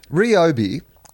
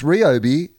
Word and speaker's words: Ryobi: Ryobi is how it's pronounced. Podcast Mike has put that up on the little message window. And it says, it's Ryobi, source, I can read Japanese.